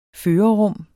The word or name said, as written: førerrum